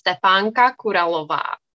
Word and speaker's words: Stepanka Kuralova: So I come from the Czech Republic. So it is a Czech name and it's Stepanka Kuralova.